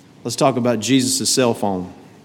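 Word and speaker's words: phone (φωνή): The word voice right there is the Greek word phone. We would pronounce that as phone.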